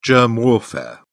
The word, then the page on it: germ warfare: British English